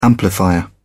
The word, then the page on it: amplifier: British English